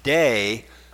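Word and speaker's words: day (δεῖ): That is the short little Greek word pronounced day.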